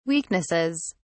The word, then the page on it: weaknesses: British English